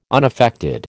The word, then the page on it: unaffected: American English